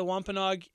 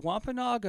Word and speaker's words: Wampanoag: The Hockomock Swamp, named by the Wampanoag Indians. – Is it Wampanoag or Wampanoag? – I'm just trying to figure out if those are two different tribes or two different pronunciations.